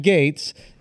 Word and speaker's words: Gaetz: How do you pronounce his shit? – I think Gaetz. Gaetz. – Yeah, Matt.